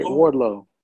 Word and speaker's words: Wardlow: Like um and then uh Warlord, right? Is, am I saying his name right? I, I feel like I keep saying his name wrong. – Warlord. Yeah, right. Wardlow. – Wardlow. Okay.